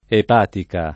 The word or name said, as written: epatica